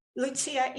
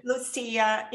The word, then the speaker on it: Lucia: And I didn't even ask you, how do you say your name? Is Lucia correct? Or do you have a different... – Lucia in Italian. – Okay, good. – Lucia in Portuguese.